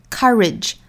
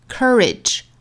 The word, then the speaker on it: courage: 那我们注意英音的话呢，它是一个倒三角的 R 的发音，所以是 ka, courage, courage. 那美音倾向于发 cur, courage, courage. 这是一个小词的英美区别。